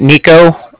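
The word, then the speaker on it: Niko: Can you let him know that Nico, that's N-I-K-O, from the Bank of Cardiff called? And Cardiff is spelled C-A-R-D-I-F-F, as in Frank Frank.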